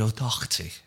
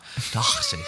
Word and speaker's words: O'Doherty: David O'Doherty is here. I have to say it like this now. O'Doherty.